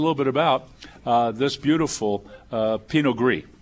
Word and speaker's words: Pinot Gris: Well, the next wine that we're going to have, which is Pinot Gris, and that's spelled Pinot, we know P-I-N-O-T. Gris is spelled G-R-I-S.